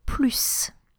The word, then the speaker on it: plus: For the rest, that is to say when you compare nouns or verbs, you are going to pronounce the final S, so plus.